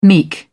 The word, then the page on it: meek: British English